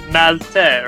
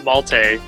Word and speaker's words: Maltais: It's French-Canadian, so it's Maltais, or Maltais, basically silent S.